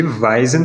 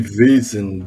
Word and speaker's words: Vaesen: Eu sou a favor de, aqui no Brasil, a gente chamar de Weizen, porque é assim que ele está escrito. pronuncia o A e o E separado.